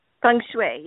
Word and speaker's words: feng shui: The feng shui? Is that proper? – Feng shui, yes. You know, a lot of people actually pronounce it a little bit differently, but yes, feng shui.